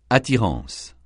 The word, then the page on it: attirance: francais